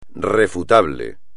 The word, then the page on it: refutable: espagnol